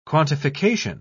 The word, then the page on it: quantification: British English